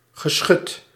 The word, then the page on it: geschut: [ɣə.ˈsxœt]